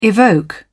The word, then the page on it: evoke: British English